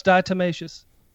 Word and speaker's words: diatomaceous: Okay, she's saying that you said it wrong. It's dichotomous earth. – No, it's diatomaceous.